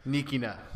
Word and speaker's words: Nikina: What is the correct pronunciation of your last name? – Actually, it's Frank Nikina. Thank you, guys. Frank Nikina. – Nikina. – Okay. – I was a lot closer with the A than nicotine.